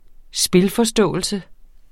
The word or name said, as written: spilforståelse